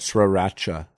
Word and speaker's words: Sriracha: Sooner Magic says, see flavor of the month, pumpkin spice, habanero, sriracha. Am I saying that right? – Sriracha. Sriracha. – Sriracha. – Sriracha. – That's right. I've been corrected before.